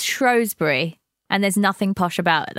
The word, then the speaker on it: Shrewsbury: Andy is not posh, but is correct in the pronunciation of Shrewsbury, not the phonetic Shrewsbury. But don't worry, Jules, it's a common mistake folks not from Shropshire make.